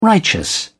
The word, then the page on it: righteous: British English